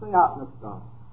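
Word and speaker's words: theopneustos (θεόπνευστος): The word that Paul uses here may be pronounced theopneustos.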